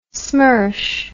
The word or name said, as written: smersh